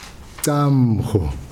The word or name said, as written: camm ho